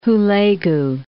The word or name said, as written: Hulegu